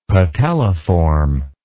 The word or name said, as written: patelliform